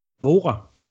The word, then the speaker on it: Worre: So how do you say your last name? – Vora.